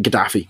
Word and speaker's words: Gaddafi: Gaddafi.